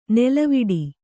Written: ನೆಲೆವೀಡು

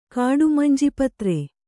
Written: ಕಾಡುಮಂಜಿಪತ್ರೆ

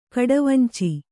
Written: ಕಡವಂಚಿ

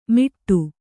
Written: ಮಿಟ್ಟು

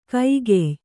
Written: ಕಗ್ಗಾಯಿ

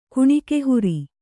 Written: ಕುಣಿಕೆಹುರಿ

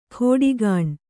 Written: ಖೋಡಿಗಾಣ್

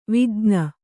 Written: ವಿಜ್ಞ

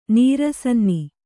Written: ನೀರಸನ್ನಿ

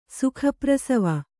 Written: ಸುಖಪ್ರಸವ

